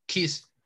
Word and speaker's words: Keys: Keys. – porque es como, llave, ¿no? Key. Exactamente. Bueno, esta, esta mujer, hermosa por cierto, tiene una canción que se llama...